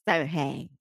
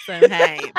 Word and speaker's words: Samhain: Samhain. Samhain, ok.